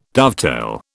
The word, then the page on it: dovetail: British English